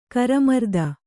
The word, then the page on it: ಕರಮರ್ದನ: Kannada